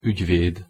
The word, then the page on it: ugyved: Hungarian